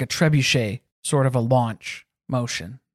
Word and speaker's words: trebuchet: You used the proper French pronunciation. – Well. – Pronunciation. – Okay. Don't give me too much credit. – Instead of trebucket.